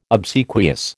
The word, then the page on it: obsequious: American English